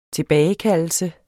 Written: tilbagekaldelse

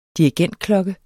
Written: dirigentklokke

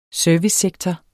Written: servicesektor

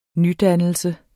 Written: nydannelse

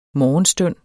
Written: morgenstund

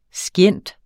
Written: scient.

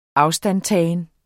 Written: afstandtagen

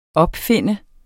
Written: OPFUNDNE

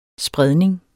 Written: spredning

